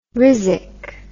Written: rhizic

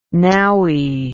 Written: nowy

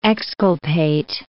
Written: exculpate

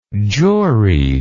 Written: juried

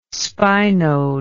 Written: spinode

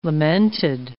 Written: lamented

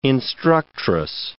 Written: instructress

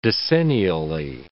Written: decennially

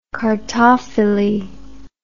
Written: cartophily

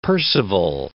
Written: Perceval

